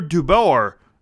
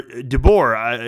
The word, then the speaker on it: DeBoer: Vegas fired Peter DeBoer. Or DeBoer is how you say it.